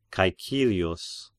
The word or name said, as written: Caecilius